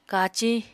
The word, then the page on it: kaciñ: apatani